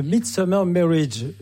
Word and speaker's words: The Midsummer Marriage: C'est The Midsummer Marriage, j'ai mal prononcé tout à l'heure,